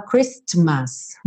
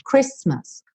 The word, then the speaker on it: Christmas: A letra T não é pronunciada, então você não fala Christmas, você vai falar Christmas, Christmas, tá?